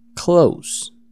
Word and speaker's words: clothes: Siguiente, es la palabra, se escribe clothes, que significa ropas, pero se pronuncia clothes. Repeat after me, clothes. Very good, clothes. De hecho, se pronuncia igual que el verbo cerrar la puerta, close.